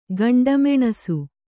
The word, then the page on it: ಗಂಡಮೆಣಸು: kannada